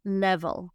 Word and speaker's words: level: Nivel se dice en inglés level, se parece un poquito level, pero es muy importante, muchachos, que pronunciemos la V correctamente. Va, va, va, va, con vibración vamos a hacer la vibración?